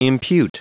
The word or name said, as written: impute